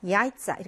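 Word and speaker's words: 야이짜 (яйца): 근데 복수로 만들면 오 하니까 어미가 이제 복수 어미가 붙었는데 강세가 맨 앞에 와서 얘는 자기 위에 강세가 있으니까 자기 음가를 내겠죠? 야이짜 이렇게 발음이 나죠. 이쪼, 야이짜.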